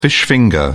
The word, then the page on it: fish finger: British English